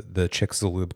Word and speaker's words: Chicxulub: And they call this the Chicxulub Crater. Am I saying that right? – Yep.